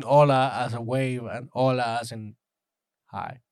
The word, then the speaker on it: hola: It's silent. It's like in Spanish. I was treated in the DR, so H is silent. Sorry, we don't say hola. We say hola.